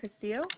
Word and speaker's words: Castillo: Angelica Castillo.